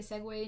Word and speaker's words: segue: S-E-G-U-E. I literally am so stupid. I would see that word on, like, writing, and I would be like, Segue? Yes. Segue. I don't know, like, when I figured it out, but I was like, what the actual fuck? Like, I've been saying this wrong.